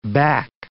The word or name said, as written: back